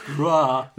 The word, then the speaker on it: Roar: But the thing is that he's Norwegian, so I imagine it's pronounced something like Roar. – Roar.